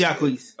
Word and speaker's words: Jacquees: No, it's Jacquees.